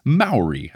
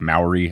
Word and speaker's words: Maori: The correct pronunciation of a word I was trying to say, and I hope I'm saying it right this time based on their instructions, is Maori. I think I was saying like Maori, and that's not right.